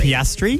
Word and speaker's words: Piastri: Oscar Piastri. – Piastri? – Piastri!